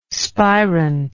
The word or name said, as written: spirant